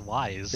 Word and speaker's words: Ys: I think it's pronounced Ys. – Ys. – Maybe more commonly pronounced Ys, but I guess Ys is more... – What are you talking about? It's commonly pronounced as Wise. – It's Wise. It's Yes, Wise. The Yes series.